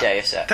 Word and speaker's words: Deus Ex: Deus Ex.